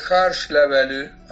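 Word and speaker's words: Hárslevelű: La segunda cepa en importancia, que tiene cerca del 25% de los viñedos, quizás un poco más, es el Harshlevelű, así se pronuncia en, ajá, húngaro, significa hoja de tilo en realidad, porque la hoja de la vid se asemeja un poco a la hoja del tilo.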